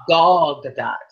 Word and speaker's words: Gal Gadot: It is Gadot. And I believe it's Gal Gadot. It's not Gal.